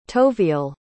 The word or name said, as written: toviel